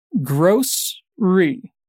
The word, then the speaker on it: grocery: The pronunciation here is a bit different, so we can kind of divide it in two. Gross-ry. Repeat. Gross-ry.